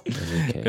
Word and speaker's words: Enrike: And it's spelled E-N-R-I-K-E, right? – Enrique. Yep.